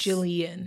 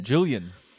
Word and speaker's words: Jillian: It's technically three syllables. – Yes. – Jillian. – Jillian. – And it's spelt kind of like the author of the book we're going to talk about close to. Jill, I-A-N, Jill and Iain, so yeah.